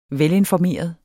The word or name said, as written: velinformeret